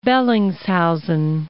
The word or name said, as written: Bellingshausen Sea